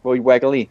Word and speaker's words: Wegerle: I'm not sure on the pronunciation there, but he's actually an American guy, you know, Jerry. Roy Wegerle. – Hey, if he's American, that is exactly how you pronounce that.